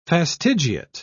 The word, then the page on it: fastigiate: British English